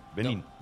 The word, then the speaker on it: Benín: Benín. – Acento en la I tiene.